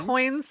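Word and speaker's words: quoins: And then we also use what are called coins, and it's coins spelt with a Q-U. So it's Q-U-O-I-N-S.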